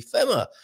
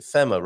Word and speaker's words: FeMa: FeMa. I'm pretty sure that's how we would pronounce it, FeMa, rather than FEMA, as in the American organization FEMA.